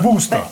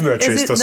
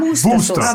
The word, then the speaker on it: Worcester: Ähm, Wuster. Ja, genau. – Das weiß ich. Genau, da darf man nicht Worcester Sauce. Da darf man nicht Worcester Sauce. Nein, Wuster Aber so, so wie die Wuster Sauce ja?